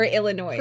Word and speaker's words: Illinois: I understand, but like a lot of words in the English language have silent letters. – I don't look. – Illinois is one of those.